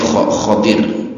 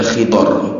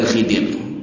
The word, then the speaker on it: Khadir: Kalau yang masyhur itu bacanya pakainya Khadir. Jadi bisa dibaca Khadir, bisa dibaca Khidor. Tapi yang masyhur Khadir, pakainya Kho. Harokatnya difathahkan Kho-nya. Walaupun di kita seringnya dikasrokan Khidir. Tapi yang benar bukan Khidir, tapi bacanya Khadir.